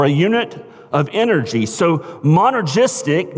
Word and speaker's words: monergistic: Our salvation is the monergistic work of God. Like that? Monergistic. Let me spell it for those of you who take notes. M-O-N as in Nancy, E-R-G-I-S-T-I-C, monergistic.